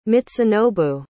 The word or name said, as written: mitsunobu